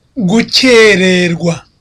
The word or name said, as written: gukererwa